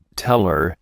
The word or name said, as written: teller